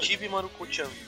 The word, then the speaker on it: Chibi Maruko-chan: É, sobre a adaptação do mangá em Braille, o primeiro título trabalhado foi Chibi Maruko. Como é que fala isso aí? – É Chibi Maruko-chan. – É isso aí.